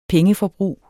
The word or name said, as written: pengeforbrug